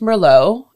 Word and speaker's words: Merlot: I started, like, I learned that Merlot is actually pronounced Merlot and it's a red wine.